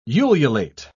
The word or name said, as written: ululate